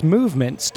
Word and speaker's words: MVMT: You know, MVMT watches pronounced movement was started by two broke college kids,